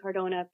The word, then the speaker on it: Cardona: And my Instagram profile is @ Christine Cardona, PA. And my name is spelled K-R-Y-S-T-I-N-E. My last name is C-A-R-D-O-N-A.